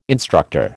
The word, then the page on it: instructor: American English